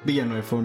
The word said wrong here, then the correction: Villeneuve: no sé cómo se pronuncia. Villeneuve, no, sí es Y pues esas son nuestras recomendaciones para esta semana.